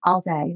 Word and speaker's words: Alday: I want to introduce you to Rachel Alday.